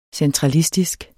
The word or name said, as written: centralistisk